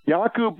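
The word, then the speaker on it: Jacob: His name is spelled J-A-C-O, Yaku, pronounced like Yahoo email without the H. So Yakub Buyans, B-O-O-Y-E-N-S.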